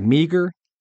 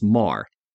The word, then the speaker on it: Meagher: Now, Marr, this is, again, Thomas Francis Marr, and his last name is spelled M-E-A-G-H-E-R. And I've heard it pronounced a variety of ways, but it looks like meager, but I've always heard it pronounced Marr.